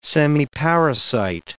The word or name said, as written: semiparasite